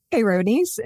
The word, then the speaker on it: Peyronie's: How do you pronounce it? – Peyronie's. – Peyronie's.